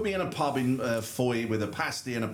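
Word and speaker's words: Fowey: I did tell you, I used to holiday in Foy, which I've pronounced correctly. – Correct. – I've been reliably informed.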